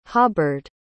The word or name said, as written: hobbard